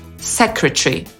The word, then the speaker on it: secretary: Brytyjczyk użyje trzech sylab, czyli powie secretary. – You are now under my command. You will retain your present rank, of course. My secretary has all the details. – Ale Amerykanin użyje czterech sylab. I tutaj w tym samym filmie aktor amerykański wymawia to słowo jako secretary.